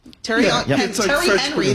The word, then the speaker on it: Thierry Henry: And they also hired, is it Thierry Henry? – Yeah. – Is this how you say it? – Terry Henry, the American way, former star player as its director of football.